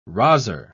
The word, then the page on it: rozzer: British English